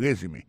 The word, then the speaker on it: resume: I see from your resume, moi, ouais, dans CV ou dans curriculum vitae, regardez qui j'ai prononcé mon résumé. I see from your resume. Accent tombé sur le premier syllabe là.